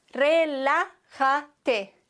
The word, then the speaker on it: relájate: Relájate. Wenn wir das T weglassen, dann ist die vorletzte Silbe hier. Relájate. T weg. Rela. Auf dem A muss der Akzent sein.